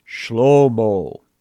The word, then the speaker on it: Shlomo: He has chosen Shlomo. That's the Hebrew, or my close approximation of the Hebrew pronunciation of the name we say Solomon.